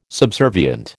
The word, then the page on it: subservient: American English